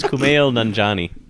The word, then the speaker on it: Kumail Nanjiani: And it's Kumail Nanjiani. – That's what I said.